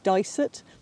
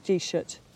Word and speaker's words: Disert: In a lot of parts of the country, it's called Disert, but here in Donegal, it's more like Disert, and it's just a pronunciation difference.